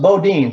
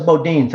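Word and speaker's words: Boudin: Pronounced Bodine. Thank you. Thank you. You're really impressing me, my man. Yeah, Chesa Bodine.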